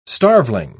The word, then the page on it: starveling: British English